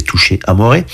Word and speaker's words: Touché Amoré: Alors c'est, ça se prononce en français, il y a des accents sur les E, donc c'est Touché Amoré.